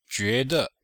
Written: jue2de5

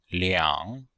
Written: liang3

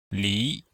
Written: li2

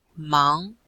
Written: mang2

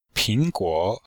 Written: ping2guo3

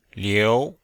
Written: liu2

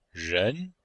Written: ren2

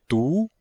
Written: du2

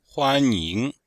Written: huan1ying2

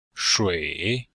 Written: shui3

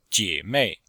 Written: jie3mei4

